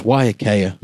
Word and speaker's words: Waiakea: Is it Waiakea? – It's Waiakea.